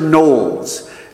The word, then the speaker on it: Knollys: The minister who performed the ceremony was Hansard Knowles. And it's spelled K-N-O-L-L-Y-S. It looks like Knollys, but it's pronounced Knowles. Hansard Knowles.